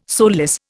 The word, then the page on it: soulless: Indian